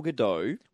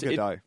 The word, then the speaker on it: Gadot: It's Gal Gadot. – It's like Bridget Bardot.